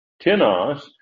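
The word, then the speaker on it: tinos (τίνος): If you have the accent on the first syllable, that is on the ih sound, tinos, it makes it what is called a pronoun, that is, whoops, I'm looking at the wrong one.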